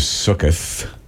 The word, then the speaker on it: Sukkot: I grew up, it was Sukkoth. – Sure. – With a TH, different pronunciation, Sukkot. – Right. – Feast of Tabernacles. – And you can say it either way, sure.